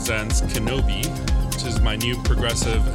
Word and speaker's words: KNBI: It's KNBI, pronounced Kenobi.